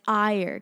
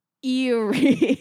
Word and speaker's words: Eyre: Into the coast of Erie. And by the way, say it right. It's Ire because it's spelled E-Y-R-E. And so like, I guess to a stupid American, it would be Erie.